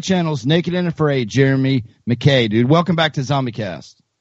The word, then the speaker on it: McCaa: But uh, you know, last week we had Mr. Jeremy McCaw, McCaw, M C C I don't know, M C C A A. McKay though, pronounced McKay. McKay, that's right, McKay.